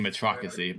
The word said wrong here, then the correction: meritocracy: but I can't say it right anyway. – Meritocracy. – Sorry, I was like, I'm missing a syllable.